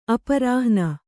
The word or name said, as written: ಅಪರಾಹ್ಣ